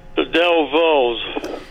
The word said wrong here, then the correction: Dovells: Del Vos. I'm yeah, not saying it right. – That's all right. You know it's the Dovells, and I'll give it to you for that.